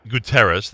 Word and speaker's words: Guterres: The UN, and you mentioned the United Nations earlier, Mr. Guterres, if that's how you pronounce it, the new Secretary General. – Guterres, thank you.